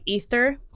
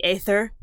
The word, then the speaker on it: ether: Um, and so we still see it in words like ether or uh, people want to pronounce it into the aether, but it's actually just ether, ether.